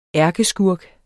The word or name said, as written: ærkeskurk